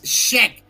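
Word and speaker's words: Scheck: Barry Sheck. Ah, eh, entendí otra cosa, Anuar. Eh, bueno, por tu mente, es check Se escribe S-C-H-E-C-K. E check.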